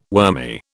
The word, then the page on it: wormy: British English